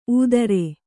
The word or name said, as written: ಊದರೆ